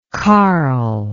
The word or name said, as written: karl